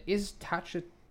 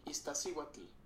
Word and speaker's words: Iztaccíhuatl: Iztaccíhuatl.